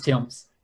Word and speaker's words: Thames: Is it pronounced Thames? It's pronounced Thames.